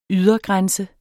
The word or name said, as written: ydergrænse